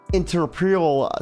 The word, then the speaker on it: entrepreneurial: entrepreneurial salesman books out there. I cannot pronounce that word to save my life. – Entrepreneurial. – Thank you.